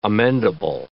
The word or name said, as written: amendable